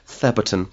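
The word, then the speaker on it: Thebarton: It's written the Barton, but pronounced Theberton.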